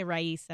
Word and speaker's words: Raisa: It's Francia Raiza. – So I say it right, but I can't do the R. – Yeah, you can say Raiza.